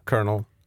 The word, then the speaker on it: colonel: Colonel. Men det är också, man kan också stava popcornkärna med K-I-R-N-I-L. Colonel. – Mm. – Så, och de båda uttalas exakt likadant.